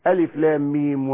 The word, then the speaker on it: الم: أنت في أول البقرة ماذا تقول؟ ألف لام ميم، هذا ليس نطق الحرف، ليس صوت الحرف.